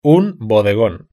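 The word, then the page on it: un bodegon: European Spanish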